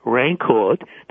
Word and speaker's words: Rancourt: You can access him very simply by Googling his name, which is Denis, the S is silent, D-E-N-I-S, Rancourt, the T is silent, R-A-N-C-O-U-R-T.